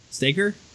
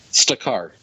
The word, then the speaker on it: Stakar: Staker? – Stakar.